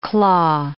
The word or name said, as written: claw